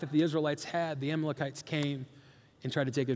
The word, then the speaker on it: Amalekites: there was a nation called the I always say this wrong. It's Amalekites. It's Amalekites, okay?